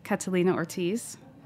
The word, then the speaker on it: Catalina Ortiz: The organization was founded by Batista and Morales Catalina Ortiz.